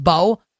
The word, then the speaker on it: Mbow: Like, the Giants might have something in Marcus Bowe, and his name is spelled M-B-O-W, pronounced Bowe.